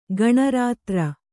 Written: ಗಣರಾತ್ರ

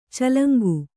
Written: ಚೆಲಂಕು

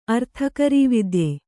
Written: ಅರ್ಥಕರೀವಿದ್ಯೆ

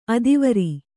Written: ಅಧ್ವರಿ

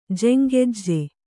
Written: ಜೆಂಗೆಜ್ಜೆ

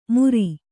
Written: ಮುರಿ

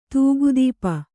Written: ದಿಕ್ಕುತಪ್ಪು

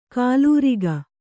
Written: ಖಲೂರಿಕೆ

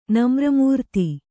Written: ನಮ್ರಮೂರ್ತಿ

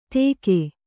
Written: ತೆಗೆ

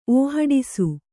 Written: ಓಹಡಿಸು

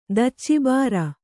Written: ದಚ್ಚಿಬಾರ

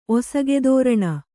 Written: ಒಸಗೆದೋರಣ

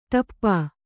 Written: ಠಪ್ಪ

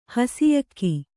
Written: ಹಸಿಯಕ್ಕಿ

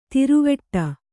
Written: ಥರವಿಡಿ

